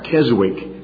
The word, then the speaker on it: Keswick: Perhaps you've heard that word, K-E-S-W-I-C-K. Most Americans pronounce that Keswick, but the W is silent, it's Keswick.